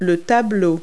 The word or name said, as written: picture